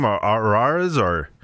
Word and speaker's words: Arraez: Arraez.